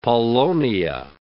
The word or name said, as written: paulownia